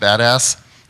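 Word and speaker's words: BDAS: It's pronounced badass.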